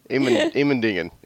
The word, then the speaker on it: Immendingen: Immendingen. Immendingen.